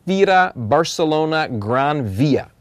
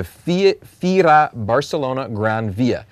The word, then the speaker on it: Fira Barcelona Gran Via: Fira Barcelona Gran Via. Did I say that right? – Nicely done. – Okay. The Fira Barcelona Gran Via to be able to register and to get yourself ready to go.